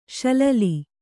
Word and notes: ಶಿಲಾಲಿ: śilāli

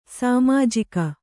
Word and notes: ಸಾಮಾಜಿಕ: sāmājika

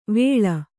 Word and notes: ವಳೆ: vaḷe

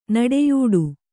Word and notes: ನುಡಿಯಾಟ: kannada

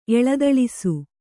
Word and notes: ಎಳದೊಳಸಿ: kannada

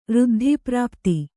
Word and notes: ಋದ್ಧಿಪ್ರಾಪ್ತ: řddhiprāpta

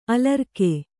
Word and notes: ಅಳರ್ಕ: aḷarka

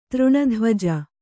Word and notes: ತೃಣಧ್ವಜ: třṇa dhvaja

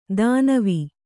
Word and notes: ಧನ್ವಿ: dhanvi

